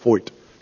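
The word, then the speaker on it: Feucht: It looks like Fout, but it's pronounced Foyt.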